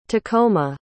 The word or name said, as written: tacoma